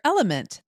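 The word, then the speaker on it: LMNT: Sarah, we're welcoming our sponsor, Element. That's spelled L-M-N-T, a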